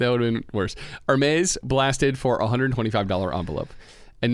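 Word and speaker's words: Hermès: That's how you pronounce it, right? Hermes? – Oh, come on. Hermes.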